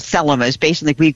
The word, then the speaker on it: Thelema: And what she was asking about is something called Thelema. T-H-E-L-E-M-A with the emphasis on the first syllable.